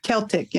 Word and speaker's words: Celtic: And so when I, I read that you left Christianity and found Celtic spirituality, um, is that how you pronounce it? Celtic? Celtic, yeah. Celtic. Okay.